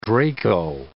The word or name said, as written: Draco